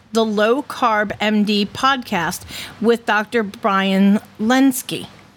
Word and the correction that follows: Lenzkes: Yeah, Lens- Lenzkes. But Lenzkes.